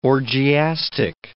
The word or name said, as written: orgiastic